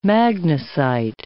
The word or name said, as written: magnesite